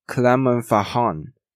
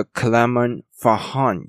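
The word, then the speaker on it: Clermont-Ferrand: 我对于他们法国的学校的发音没有到非常的理解，但我尽量把它念出来，就是 Clermont Clermont Ferrand。他们的 R 呢是发 H 的音，所以呢叫做 Clermont Ferrand University。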